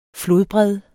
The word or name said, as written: flodbred